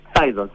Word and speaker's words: Phaidon: Phaidon, sí.